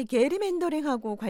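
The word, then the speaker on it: gerrymandering: 자, 이 사안이 이른바 게리맨더링하고 관련이 있는 거죠. – 맞습니다. 이 미국인들이 보통 제리맨더링이라고 발음하는 건데요.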